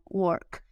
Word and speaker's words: work: La palabra trabajo también termina en K y tiene que sonar work.